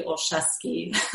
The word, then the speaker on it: Olszewski: It is just the uh, LinkedIn forward slash in forward slash Leah Marie Olszewski.